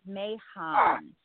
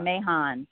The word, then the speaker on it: Mahon: Kind of. It's Mahon. It's very confusing. – Mahon. – It's Mahon.